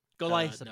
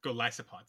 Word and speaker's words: Golisopod: Oh, have you got your 400 candies, y'all, for Golisopod into Wimpod, or Wimpod into Golisopod? – Golisopod. – No. Golisopod, sorry.